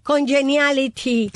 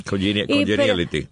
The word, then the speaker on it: Congeniality: Con Genali, difícil de pronunciar. – ¿Cómo es? ¿Cómo se dice? Congeniality. – Congeniality.